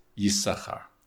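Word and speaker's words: Yisachar (יששכר): But in the same way that Leah only enunciated one of the Sachars, we also only enunciate one of those letter sins, and hence it's written with two, but we pronounce it just Yisachar.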